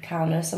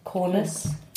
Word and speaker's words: Kaunas: So we've mentioned, obviously, that we're in Kaunas. I'm still saying it wrong. Kaunas.